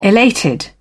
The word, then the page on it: elated: British English